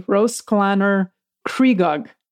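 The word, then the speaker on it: Rhosllannerchrugog: And it's pronounced roughly Roslaner, Trugog. – Or Trugog. – Yeah, Trugog could be, yes, either one. Second, now listen, it is spelled Y-N-Y-S-Y-B-W-L.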